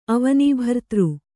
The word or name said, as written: ಅವನೀಭರ್ತೃ